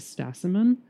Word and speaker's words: stasimon: And then you talk about this concept of the stasimon, mm-hmm. – which I might have pronounced that wrong. – Sounds good to me.